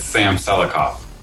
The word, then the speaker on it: Sam Selikoff: It's Sam Selikoff.